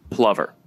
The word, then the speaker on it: Plover: Okay, Plover Under, like over under, except Plover is spelled like over, except it's pronounced Plover.